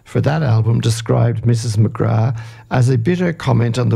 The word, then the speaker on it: McGrath: And we'll have to forgive the boss's pronunciation, Mrs. McGrath. Indeed, of course, it's Mrs. McGrath.